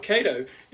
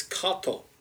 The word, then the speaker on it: Kato: And that's right, that's the correct pronunciation, folks. It's not Kato, it's Kato. That's right. Those that speak Japanese know what I'm talking about.